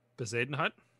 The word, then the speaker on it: Bezuidenhout: Can you try to pronounce the South African's name that you're trying to say? – Bezuidenhout. – Yeah, that's pretty good. – It's pretty close. Bezuidenhout.